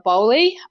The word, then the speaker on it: Bowley: bowley um, I'll just point out how to spell that because people always get it wrong.